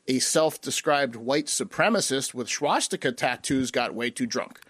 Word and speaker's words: swastika: Swastika. No, it's it's just SW. – Yeah, you're adding a whole sound. – Yes, you're adding an SCH before the W.